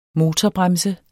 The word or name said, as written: motorbremse